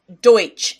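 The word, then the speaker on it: Deutch: Wiki says Deutsch is how it's pronounced. D O Y T C H.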